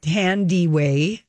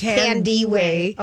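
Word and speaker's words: Thandiwe: And a few years ago, she told, it's pronounced Tandaway. – It's not Tandaway. – No, it's Tandaway. – Okay. – It was misspelled in her first film credit. T-H-A-N-D-I without the W.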